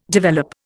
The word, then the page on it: develop: Indian